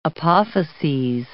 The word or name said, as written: apophyses